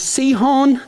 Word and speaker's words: Sihon: A guy named Sihon, that's how I'm going to pronounce it. You pronounce it whatever wrong way you want to. S-I-H-O-N, Sihon.